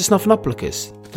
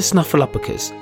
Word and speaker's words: Snuffleupagus: And all Big Bird wants for Christmas is Mr. Snuffleupagus. I said that wrong. Let's try again. Mr. Snuffleupagus.